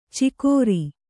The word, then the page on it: ಚಿಕೋರಿ: cikōri